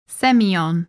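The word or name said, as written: Sémillon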